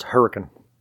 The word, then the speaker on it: Hurricane: It's spelled Hurricane, but it's pronounced Hurricane.